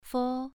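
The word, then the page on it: fo: tone 2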